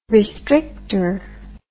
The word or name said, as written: restrictor